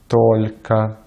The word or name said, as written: только